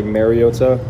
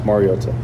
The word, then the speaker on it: Mariota: I think it's been said over the years, like Mariota, which is incorrect, and it's pronounced Mariota.